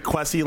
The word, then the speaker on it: Kwasi: Think like Quasi, like Jesse.